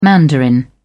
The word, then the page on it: mandarin: British English